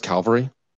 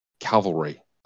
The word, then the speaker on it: cavalry: Did I mispronounce Calvary? Cavalry.